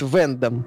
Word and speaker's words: Вендом: Голову, кстати, зовут Вендом. На ударение на первый слог и пишется в одно слово.